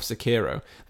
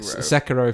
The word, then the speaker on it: Sekiro: Sekiro if you're doing the Japanese. Sekiro if you're doing the Japanese pronunciation.